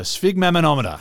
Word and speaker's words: sphygmomanometer: Oh, the sphygmomanometer.